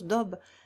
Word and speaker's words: Dob: L'animal qu'on appelle le poisson de sable et que les Arabes nomment Dob, on prononce Dob, est une autre sorte de gros lézard qui vit dans les sables.